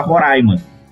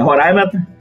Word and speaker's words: Roraima: pronúncia era Roraima. Só que as duas formas estão corretas.